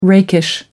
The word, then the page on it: rakish: American English